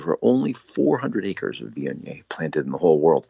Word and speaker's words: Viognier: It's spelled V-I-O-G-N-I-E-R. Viognier is how it's pronounced.